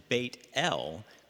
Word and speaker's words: Bethel: Now, Bethel is actually pronounced Beit El, meaning the house of the Lord.